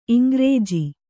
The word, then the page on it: ಇಂಗ್ರೆಜ: Kannada